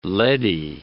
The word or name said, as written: leady